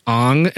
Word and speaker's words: Aang: I believe it's pronounced Aang, M. Night Shyamalan.